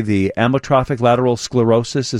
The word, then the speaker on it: amyotrophic lateral sclerosis: The lingering injury led to a diagnosis months later of, and now, how do you say, the amyotrophic lateral sclerosis? Is that it? – That's correct.